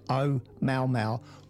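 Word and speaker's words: Oumuamua (ʻOumuamua): So I spoke to our linguistic department when I first got here, and apparently that is a pronunciation is Oumuamua, which is a Hawaiian word which means scout and reach.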